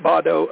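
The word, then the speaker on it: Baudot: They say pronounce it Bado. I've never pronounced it that way, which is where the term baud comes from, though.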